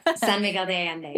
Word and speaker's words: San Miguel de Allende: Your friends invited you to a city called San Miguel de Allende. Is I saying that right? – Yeah, San Miguel de Allende, yes.